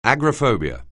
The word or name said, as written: agoraphobia